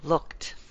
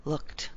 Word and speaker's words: looked: But here, one syllable. Looked. Verbs that rhyme. Verbs that end with a K sound usually end with a T past tense. Looked. Booked. Shook. irregular verb there. But bake, baked. Most of your were verbs that end with a K sound or K. Go to a T and keep one syllable.